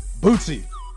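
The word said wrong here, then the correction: Boosie: My bad. I didn't realize there was a T in there. – There isn't. – Oh, wait. There's not a T, but you pronounce a T? – I've never heard anybody say Boozy. – It's B-O-O-S-I-E.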